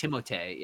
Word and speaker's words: Timothée: Do you know we've been pronouncing his name wrong this entire time? He just didn't want to correct anybody. – Timothée. – It's Timothée, yeah. – Yeah, it's French.